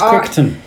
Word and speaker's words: Crichton: It's Crichton.